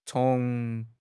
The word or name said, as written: cong2